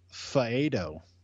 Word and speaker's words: Faedo: We have drafted Alex Faedo. Am I saying that right? – Sure.